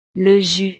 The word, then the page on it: le jus: frances